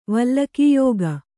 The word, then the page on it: ವಲ್ಲಕೀಯೋಗ: vallakīyōga